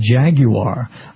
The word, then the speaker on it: Jaguar: pronounce Jaguar the way they do in the United Kingdom, and that's a Jaguar.